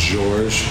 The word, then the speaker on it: Georges: In France, it's pronounced Georges. But I wasn't really fond of being called Georges. – Yeah, being called that when I was younger, so it's just George.